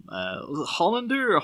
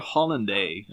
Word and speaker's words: Hollander: Hollander or Hollanday? I'm not too sure what way he'd prefer it pronounced. – I've only ever heard it as Hollander.